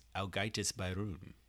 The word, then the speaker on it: Ágætis byrjun: Um, it's Sigur Rós's Ágætis byrjun. – Oh, is that how you say it? It sure is, because that's the correct way to say it.